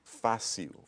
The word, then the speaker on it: fácil: Fácil. – Fácil. – And you hear that? – Fácil. – Yeah, see that L sound?